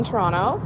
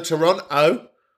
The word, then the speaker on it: Toronto: If you are pronouncing the second T in Toronto, then you have no right to comment on Toronto.